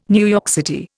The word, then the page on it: NYC: Indian